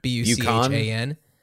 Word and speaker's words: Buchan: B u c h a n.